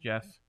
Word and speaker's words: Geoff: Steven Thompson versus Geoff, probably pronounced Jeff Neal.